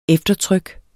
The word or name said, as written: eftertryk